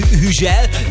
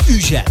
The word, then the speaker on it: Hugel: Francia nyelvtelten Hüzsel, amúgy pedig nagyon durva, de Hugel a helyes kiejtés. – Ügyel, légy szíves, jó? – Akkor Ügyel.